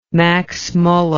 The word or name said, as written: max muller